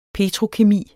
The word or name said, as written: petrokemi